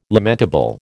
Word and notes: lamentable: American English

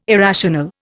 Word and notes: irrational: Indian

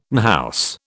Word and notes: unhouse: British English

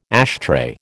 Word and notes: ashtray: American English

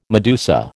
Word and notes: medusa: American English

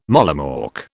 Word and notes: mollymawk: British English

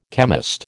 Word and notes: chemist: American English

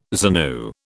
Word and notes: ZANU: British English

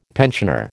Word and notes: pensioner: American English

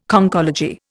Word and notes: Conchology: Indian

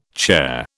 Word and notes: charr: British English

